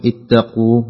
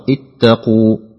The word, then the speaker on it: اتقوا: كذلك عندك كلمة اتقوا، القاف فيها شدة، فعليك أن تظهر شدة القاف، لا تقول اتقوا، لا تظهر القاف في النطق، لا يجب أن تظهر، بل يجب أن تظهر اتقوا.